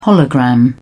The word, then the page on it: hologram: British English